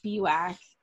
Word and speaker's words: Spiewak: It's Spiewak.